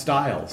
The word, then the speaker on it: Stihl: S-T-I-H-L is a tool company, and they're pronounced style.